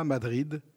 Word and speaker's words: Madrid: La tablée d'à côté refait la temporada, Madrid, Séville, Barcelone. Ils ne prononcent pas le D à la fin de Madrid, Madri, comme on dit mardi.